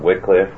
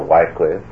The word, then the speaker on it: Wycliffe: Just a few years before the era that we shall deal with, that of John Wycliffe or Wycliffe, each pronunciation is acceptable, the Bishop of Lincoln was a man named Robert Grosstecht, a very fine scholar and writer.